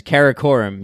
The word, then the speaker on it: Karakoram: Is it the mountain range? Karakoram? – It's Karakoram. Yes. – Karakoram. Is that how you say it? – Exactly. Yep.